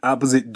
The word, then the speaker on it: opposite: She pointed in the opposite direction. ここでのポイントは、 pointed の D と次の in、これがくっついて din となります。 pointed in。そして opposite。これは silent E といって E で終わる単語の最後 E は発音されませんので、そうすると T の音で終わることになるんですね。